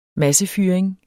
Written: massefyring

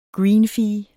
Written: greenfee

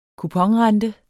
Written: kuponrente

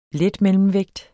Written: letmellemvægt

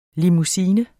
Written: limousine

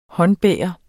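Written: håndbar

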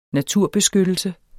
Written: NATURBESKYTTELSE

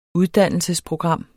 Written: uddannelsesprogram

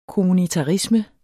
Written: kommunitarisme

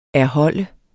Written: erholde

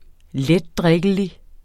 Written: letdrikkelig

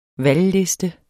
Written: valgliste

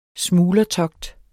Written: smuglertogt

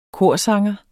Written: korsanger

